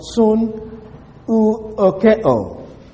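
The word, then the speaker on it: Soon, ookeo (συνευωχέω): The word that is translated feast comes from a Greek word, soon, ookeo. Soon, ookeo. It's spelled SUNEUOCHEO.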